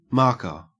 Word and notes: marker: Australian English

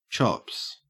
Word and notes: chops: Australian English

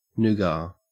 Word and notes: nougat: Australian English

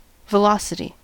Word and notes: velocity: American English

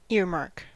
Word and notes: earmark: American English

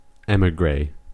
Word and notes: emigre: American English

